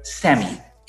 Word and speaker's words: SAMe: And before you write it down, it's shortened to the word SAMI. S-adenosylmethionine. Meh is the M-E at the end. SAMI. But it's spelled S-A-M-E. So it's spelled the way you spell the word same. – Okay. – S-A-M-E.